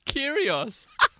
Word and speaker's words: Kyrgios: Kyrgios.